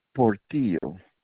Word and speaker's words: Portillo: Portillo. – Can you spell that for me? – P.S. and Paul, O-R-T-I-L-L-O.